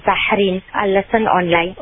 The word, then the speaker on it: Zahreen: In English, any spelling that will make you pronounce it Zahreen. So, for example, Z A H R double E N. Z-A-H-R-E-E-N, Zahreen.